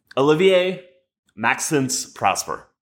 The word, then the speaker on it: Olivier Maxence Prosper: Olivier Maxence Prosper.